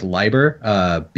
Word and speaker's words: Leiber: I'm going to go with Leiber because German diphthongs have an inverse pronunciation based on as opposed to English. So if it's EI, it is always a long I sound.